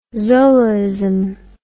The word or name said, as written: zolaism